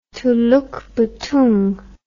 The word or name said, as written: Teloekbetoeng